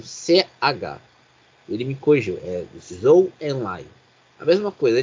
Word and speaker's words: Zhou: Eu, eu, eu fui ver a pronúncia correta do Zou. Você sabe como se fala Z, do Z e H em chinês? – Como? Eu, Zhou. Guan Yu Zhou. Exatamente.